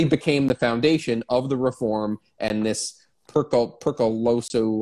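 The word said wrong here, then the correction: peruskoulu: Oh, I looked up how to say it. Let me tell you, I did the like Google Translate. It's Peruskoula.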